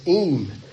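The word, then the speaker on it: im (אִם): The first word is unless, and the pronunciation of that is im.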